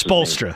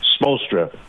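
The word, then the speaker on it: Spoelstra: Palestra. I can never pronounce Spolstra. – His name. Spolstra.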